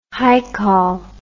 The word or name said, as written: haikal